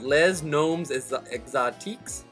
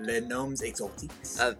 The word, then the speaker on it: Les Gnomes Exotiques: You're probably not pronouncing that right. – No, I'm almost certainly butchering it. It's a 20th century modern classical. I cannot say it right. – Les Gnomes Exotiques. – That is probably much closer.